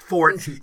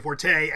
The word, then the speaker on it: forte: It should be pronounced fort, even though a lot of people say forte.